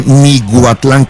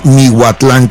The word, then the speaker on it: Miahuatlán: Porque sí me están corrigiendo que dije Miahuatlán con G, que es Miahuatlán con H. La verdad, yo sé que es Miahuatlán y para mí yo dije Miahuatlán Puebla con H.